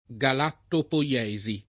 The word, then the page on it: galattopoiesi: lingua italiana